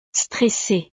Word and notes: stressee: frances

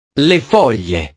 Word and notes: le foglie: italiano

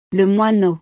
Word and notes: le moineau: frances

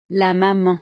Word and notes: la maman: French

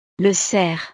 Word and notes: le cerf: frances

